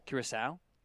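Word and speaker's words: Curaçao: Curacao.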